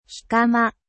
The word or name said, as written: jicama